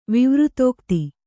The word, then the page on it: ವಿವೃತೋಕ್ತಿ: Kannada